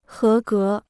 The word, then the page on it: 合格: hé gé